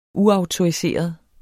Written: uautoriseret

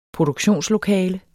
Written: produktionslokale

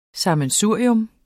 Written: SAMMENSURIER

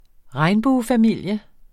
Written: regnbuefamilie

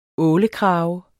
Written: ålekrage

